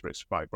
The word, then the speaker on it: RISC-V: I think it's RISC-V, right? – RISC-V, yeah.